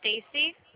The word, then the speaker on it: Stacy: Yeah, my first name is Stacy, S-T-A-C-Y.